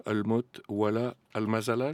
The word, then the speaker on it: Almot Wala Almazala: La plage qui a donné son titre à votre dernier album, Almot Wala Almazala, je le prononce bien ? – Très bien.